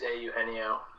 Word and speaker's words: Eugenio: Well, before you answer that, Kyle, can we get a pronunciation on, is it A. Eugenio Suarez? – It is A. Eugenio.